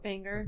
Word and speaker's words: Wenger: Venga. – You got to pronounce W as a V.